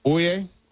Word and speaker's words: Oia: And let me tell you, if you go to Santorini, my suggestion would be to stay in the Oye, which is spelled O-I-A.